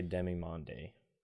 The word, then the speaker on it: Demimonde: This looks like demimonde. – Right? – Yeah. – I don't know how else to pronounce that, but... – I would say demimonde. – D-E-M-I-M-O-N-D-E.